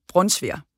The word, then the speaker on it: brunsviger: Så nu kan jeg jo lige komme til hele Danmark lige fortælle, at man udtaler det altså brunsviger.